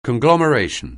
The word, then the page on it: conglomeration: anglais